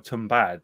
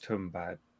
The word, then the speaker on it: Tumbbad: It looks like Tumbad, but I think it's pronounced Tumba.